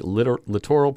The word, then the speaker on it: littoral: Erosion, water quality, littoral plants.